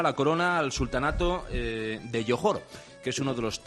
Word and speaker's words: Johor: Bueno, se dice Johor, que entiendo que no estéis muy familiarizados con la zona, pero se dice Johor, aunque se escribe con J, pero es Johor, es el príncipe de Johor.